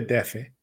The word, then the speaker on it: DF: I've never been to DEFE. But I hear that's how you say it. You don't say DF, right? Distrito Federal. You say DF.